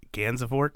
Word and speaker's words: Gansevoort: Gansevoort.